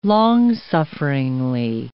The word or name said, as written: long-sufferingly